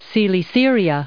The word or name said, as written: Coelesyria